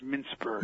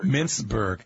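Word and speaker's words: Mincberg: We're joined now by Elliot Minkberg. He is a. Did I say that correctly? I probably did. It's actually pronounced Mintzberg. – Mintzberg.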